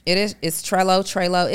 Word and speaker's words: Trello: This is really good, and I think it's pronounced Trello, but it's Trello.